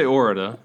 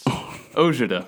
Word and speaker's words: Ourada: I say Ourada. Okay. It's Czech, and like most Czech names, it doesn't make sense. It's Ourada.